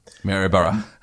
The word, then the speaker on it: Maryborough: And in fact, his hometown, how am I going to say that? – Maryborough. – Thank you. Maryborough. that's how it looks for a change.